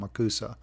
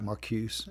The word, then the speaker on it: Marcuse: So a little bit more, I should probably tell you a little bit more by Herbert Marcuse, and I pulled up, his name is actually pronounced Marcuse. Looks like Marcuse, M-A-R-C-U-S-E.